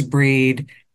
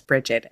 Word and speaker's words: Brigid: It specifically says that they refer to her as Breed and they spell it BREDE. So for whatever the value of Wikipedia is, they validate your pronunciation. – So, because of my training, you'll hear me refer to her mostly as Breed, and you'll probably hear Shell refer to her as Bridget, and both are correct.